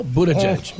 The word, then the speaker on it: Buttigieg: Or, well, did I say his name right? Or is it Buttigieg? – No, Buttigieg. – Oh. Yep. Judge-a-butt? Yep. Backwards? – Judge-a-butt, yeah.